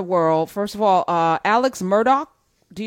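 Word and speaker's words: Murdaugh: It's Murdoch. See, I didn't even know how to say it. I was like, D-A-U-G-H. That's Murdoch. It's Murdoch.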